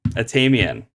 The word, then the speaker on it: Atamian: Is it Atamian? – Atamian, you got it. Third time's a charm. – All right. Yeah, I'm going to have to fix that in the editing. Atamian. All right. Rhymes with Damien.